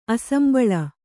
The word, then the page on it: ಅಸಂಬಳ: kannada